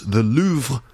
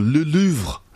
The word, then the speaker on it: Louvre: In Paris, the Louvre, that's how we say it in English. Yes, the Louvre, you probably know it. The Louvre, yes, that is my English pronunciation of the place. I wonder how you pronounce it where you are from. In French, I think it's like the Louvre, right? The Louvre or le Louvre.